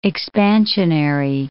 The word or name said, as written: expansionary